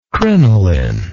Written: crinoline

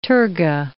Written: terga

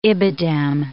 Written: ibidem

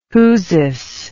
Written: whoosis

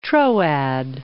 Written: Troad

